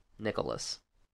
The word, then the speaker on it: Nicholas: Sam Jones, Kevin Conroy, Lou Ferrigno, and Thomas Ian Nichols. Nicholas. Sorry, I butchered his name.